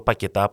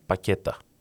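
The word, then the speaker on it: Paquetá: Ή ο Πακετά που έχει και τόνο στο Α τον λένε Πακέτα.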